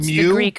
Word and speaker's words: Mu: Is it Mu? – It's the Greek pronunciation is Mu.